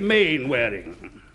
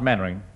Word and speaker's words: Mainwaring: You must be Mainwaring. – Oh, how do you do, Colonel? Actually, it's pronounced Mannering. – Then why the hell do you spell it Mainwaring?